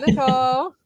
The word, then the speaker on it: Niceole: And for everybody, it is pronounced Niceole, even though it's spelled weird.